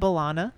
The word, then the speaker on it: B'Elanna: I said B'Elanna. – Yeah. – Which is kind of rhymes with Solana.